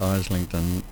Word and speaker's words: Islington: When I was in London, up in Islington, or sometimes pronounced Islington, which is a northern suburb in London, I lived there for nearly 20 years.